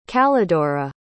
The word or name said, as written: callidora